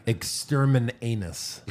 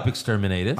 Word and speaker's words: Exterminatus: No, you didn't say it right. – Gesundheit. – Exterminanus. – Exterminanus. – Exterminanus. – Exterminatus. I looked up Exterminatus.